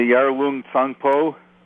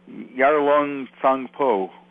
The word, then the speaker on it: Yarlung Tsangpo: Oh, you mean the Yarlung Tsangpo? – Yes. And say it again. – Yarlung Tsangpo.